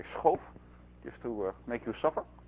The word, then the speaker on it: Schoof: So Schoof is Dutch, as am I, so the right way to say his name is Schoof and not Schoof or things you hear usually.